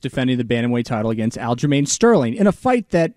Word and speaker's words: Aljamain: And he apologized to this guy, Aljamain Sterling. Did I get that right? – Nailed that one.